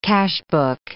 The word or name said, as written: cashbook